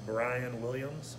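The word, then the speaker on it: Bryan Williams: Brian Williams, B-R-Y-A-N, classic spelling of Williams, the Brass Eye Lounge.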